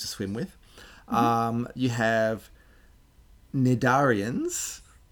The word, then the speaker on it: cnidarians: This, I'm pronouncing it weird because it starts with a C. It's got a silent C. So tenophores also has a silent C at the beginning. Cnidarians has a silent C before the N.